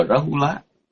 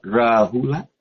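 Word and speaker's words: Rāhula: Ở đây các vị biết không có dấu, thầy cô Nhật Quỳnh hay đọc là ra hú lá. Thật ra cái âm cho chính xác là ra hú lá, chữ ra là âm dài, ra hú lá chứ không phải là ra hú lá.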